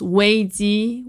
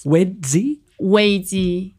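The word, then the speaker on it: Wei Ji (危机): You know, in Chinese, the word crisis, it pronounced Wei Ji, which literally is two words combined, danger and opportunities. And yes. – Wei Ji.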